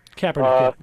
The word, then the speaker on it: Kaepernick: And if you just, let me just say this, when this, how do you say his name, Kaepernick? – Kaepernick, yep.